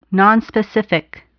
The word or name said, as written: nonspecific